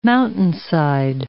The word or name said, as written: mountainside